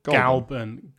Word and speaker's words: Goulburn: It's spelled Goulburn. – Goulburn. – Goulburn? Galburn. – Galburn. Goulburn.